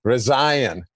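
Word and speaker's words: Reince: It's like Ryan with a Z, Resign.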